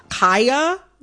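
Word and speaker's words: Khia: I am going to, in this conversation, pronounce Kaya, the rapper, with the long I.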